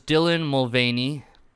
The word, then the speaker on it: Dylan Mulvaney: So basically, what happened was Dylan Mulvaney, is that how you pronounce her name? I hope so. – Yes, it is. It is.